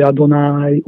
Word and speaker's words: Adonai: L'altro nome divino, il nome scritto col tetragramma sacro, quello che si legge Adonai oppure si legge Hashem,